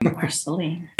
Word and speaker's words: Marceline: I'm sure I mispronounced that. – Marceline, yes. – Marceline? – You know it? I've always heard of Marceline, yep.